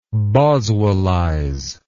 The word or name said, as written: Boswellism